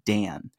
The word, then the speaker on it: Dan: like I forget everyone just calls me Dan, like one syllable.